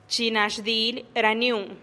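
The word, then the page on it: chi na xdilih raniun: Teotitlán del Valle Zapotec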